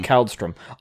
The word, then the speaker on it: Kaldstorm: K-A-L-D-S-T-O-R-M, is that it? – Kaldstrom.